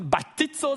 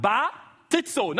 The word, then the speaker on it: baptizo: Kannst du Batizo sagen? Oh, du hast nicht richtig gesagt. Batizo. Nochmal. Batizo.